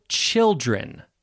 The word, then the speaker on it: children: Notice the difference in pronunciation as well. One child, that is a long I vowel sound, child. Child rhymes with wild or mild. Children, however, has a different vowel sound. Children has a short I sound. Chill, chill, chill, like the word chill, or fill, or grill, or bill.